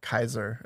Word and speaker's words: Caesar: Caesar was more like Kaiser is how they. – Oh wait, Kaiser yeah. – is how they'd pronounce it.